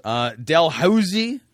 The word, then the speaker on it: Dalhousie: Dalhousie dentistry scandal.